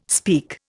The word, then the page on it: speak: Indian